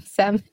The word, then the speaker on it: salmon: So we know that salmon, am I pronouncing that correctly? Salmon? – Yeah. – Yeah, salmon. – Yeah, salmon. – Okay. – That's what I say, salmon. Maybe okay. Maybe someone else listening pronounces it differently, but I definitely say salmon.